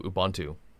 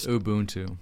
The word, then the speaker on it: Ubuntu: Jump straight into Ubuntu. – Ubuntu. Excuse his pronunciation. I'm sorry.